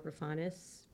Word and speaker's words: Roufanis: I'm with the Keller Williams office in Ridgewood, and my Instagram is Tara Roufanis, realtor.